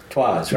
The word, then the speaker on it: Troyes: Troyes, right.